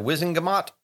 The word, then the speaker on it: Wizengamot: A rumor, basically, that Dumbledore had been sacked from the, how do you say it? The Wizengamot? – Yeah, that's how I always say it.